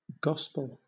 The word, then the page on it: gospel: British English